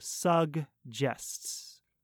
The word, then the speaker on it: suggests: So notice that in the word suggest, the first G is a hard G. It makes the g, g, g sound. But the second G is a soft G. It makes the j sound, like a J, like j, j. So suggests.